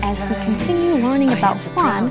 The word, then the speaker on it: Juan: You know, because his name starts with the letter J. – Right. – And so some people might think it's Juan, but actually it sounds more like an H. Juan.